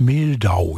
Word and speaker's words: Mehldau: Ich habe, und der spricht sich, glaube ich, wirklich so aus, als nächstes den Jazzpianisten Brad Mehldau.